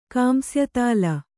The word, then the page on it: ಕಾಂಸ್ಯತಾಲ: kāmsyatāla